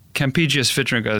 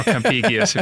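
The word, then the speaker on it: Campegius Vitringa: Is it Campigius or Campigius? What do you think? – I think his mother called him Kempe, and I think his friends would call him Vitringa, but Campigius Vitringa is good for us, or Campigius, if you prefer the classical pronunciation.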